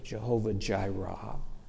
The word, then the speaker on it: Jehovah Jireh: You can pronounce it different ways. Jehovah Jireh, my provider, comes from this, the Hebrew in this verse right here.